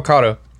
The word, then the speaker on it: Caddo: How do you say that parish? – Oh, Caddo.